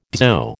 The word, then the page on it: dzho: American English